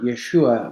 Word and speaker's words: Yeshua: It's the same Hebrew word, Yeshua. When we say Jesus, we're saying Yeshua, we're just pronouncing it in the Greco-Roman pronunciation.